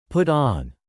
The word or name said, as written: put on